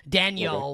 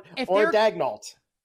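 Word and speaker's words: Daigneault: Like, I don't know if Carlisle could win Coach of the Year only because, and I know this is not how you pronounce his name, and I don't care. I like to pronounce it like this: Daniel, okay, with Oklahoma City. Like if they're Dagnault. Or Dagnall.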